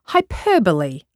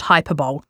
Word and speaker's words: hyperbole: Then you come across the misunderstood and usually mispronounced words like hyperbole, not hyperbole, and champ at the bit rather than chomp at the bit, and don't even get me started on literally.